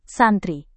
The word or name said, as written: santri